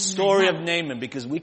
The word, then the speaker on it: Naaman: If you want to read the name properly, Hebrew is Naaman. – 如果乃曼的名字按希伯来文我们好好的读的话，叫 Naaman. – That's why it's double A. Naaman.